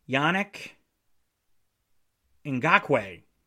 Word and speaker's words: Yannick Ngakoue: Just last week, they traded defensive end Yannick, make sure I say his name right, Yannick Ngakoue.